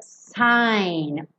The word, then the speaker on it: syne: Que essa palavra é pronunciada sign, old lang sign, esse S com som de S, não é com som de Z.